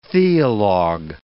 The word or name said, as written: theologue